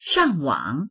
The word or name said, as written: shang4wang3